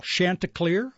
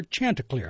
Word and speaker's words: Chanticleer: And by the way, you can pronounce that two different ways, and it's very acceptable. Chanticleer or Chanticleer.